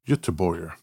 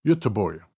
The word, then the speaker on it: Göteborg: Gothenburg? – Göteborg. – Come on. – Ed's making fun of me. Hey, Swedish people, feel free to make a comment and tell me how impeccable that pronunciation was. – Say it again. – Göteborg. – It almost sounds Slavic. Göteborg.